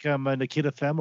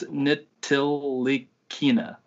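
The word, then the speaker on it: Ntilikina: I'm pretty sure I said that wrong. I added like an extra syllable. – It's Frank Nikita Femme or whatever it is. – No, it's Nitticalina. It's just hard to say.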